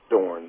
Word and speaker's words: Dorn: Yeah, so my name is Ron Dorn. That's spelled D-O-R-N.